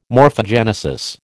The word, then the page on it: morphogenesis: American English